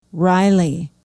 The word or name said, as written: riley